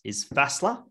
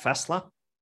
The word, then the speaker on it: Fässla: There's my favorite one is Fässla, F-A, you know, that S that looks like a B. – Yeah. – It's spelled like that, Fässla.